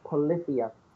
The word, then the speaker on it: Polyphia: I believe it's pronounced Polyphia. P-O-L-Y-P-H-I-A.